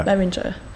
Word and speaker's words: Behringer: Is it Behringer? – Yeah. – Is that how you say it? – Yeah. – Behringer.